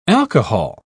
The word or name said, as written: alcohol